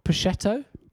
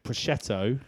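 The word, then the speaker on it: Puciato: Puccetto? – Like that, the sort of cured meat. – Greg Puccetto.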